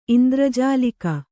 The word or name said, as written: ಇಂದ್ರಜಾಲಿಗ